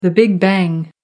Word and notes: big bang: American English

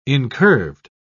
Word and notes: incurved: British English

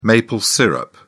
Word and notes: maple syrup: British English

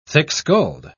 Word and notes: thick-skulled: British English

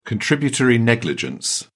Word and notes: contributory negligence: British English

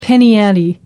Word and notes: penny ante: American English

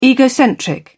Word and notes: egocentric: British English

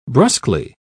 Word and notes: brusquely: British English

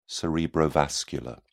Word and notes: cerebrovascular: British English

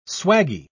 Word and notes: swaggie: British English

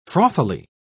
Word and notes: frothily: British English

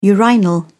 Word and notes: urinal: British English

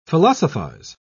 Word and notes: philosophise: British English

